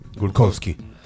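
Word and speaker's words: Glukhovsky: La respuesta correcta era Dmitri Gluchovsky, perdón, a ver si lo dije bien. – Glukowski. – Llámale Glukowski. – Glukowski.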